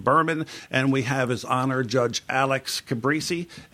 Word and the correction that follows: Calabresi: And did I pronounce that correct, Your Honor? – Calabresi. – Calabresi. I screwed it up every time, didn't I?